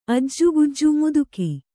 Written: ಅಜ್ಜುಗುಜ್ಜು ಮುದುಕಿ

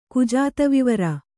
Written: ಕುಜಾತವಿವರ

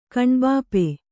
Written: ಕಣ್ಬಾಪೆ

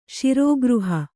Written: ಶಿರೋಗೃಹ

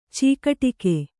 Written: ಚೀಕಟಿಕೆ

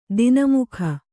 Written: ದೊಣ್ಣೆಮೂಗು

